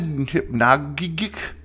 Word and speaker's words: hypnagogic: It's actually called a hyp- hypnotic, hypnagogic jerk. H Y. It's spelled H Y P N A G O G I C.